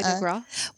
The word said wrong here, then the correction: foie gras: Well, the foie gras recipe changes sometimes. – Say it again. I never say it right. – Foie gras. – Foie gras. I say foie de gras.